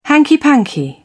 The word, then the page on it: hanky panky: British English